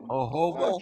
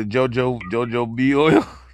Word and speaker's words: jojoba: I never knew how to pronounce that shit. I bought it a million times, and I was They probably look at me like I'm ignorant as hell. I'd be like, can I get the JoJo B oil?